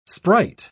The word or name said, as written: sprite